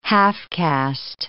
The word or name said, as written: half-caste